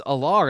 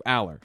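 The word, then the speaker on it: Allar: Huge relief, because we have this conversation and people are like, well, Franklin says Alar.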